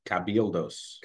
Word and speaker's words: Cabildos: Cabalillos Edición Limitada. – Cabildos. – Cabildos. – You added an extra syllable. – I want to say it's like pronouncing it like a double O. Cabildos.